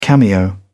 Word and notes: cameo: British English

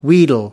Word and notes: wheedle: American English

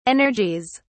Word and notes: energies: British English

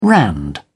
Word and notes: rand: British English

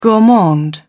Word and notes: gourmand: British English

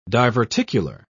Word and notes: diverticular: British English

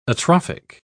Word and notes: atrophic: British English

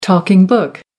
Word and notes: talking book: American English

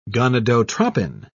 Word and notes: gonadotropin: British English

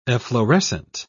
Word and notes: efflorescent: British English

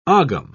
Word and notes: ogam: British English

